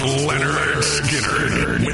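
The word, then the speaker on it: Lynyrd: Pronounced Leonard.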